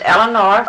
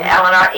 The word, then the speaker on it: Eleanor: All right, my name is Eleanor. – Now, how do you spell your name? – Eleanor, E-L-E-A-N-O-R. – See, how does that go again? – E-L-E-A-N-O-R.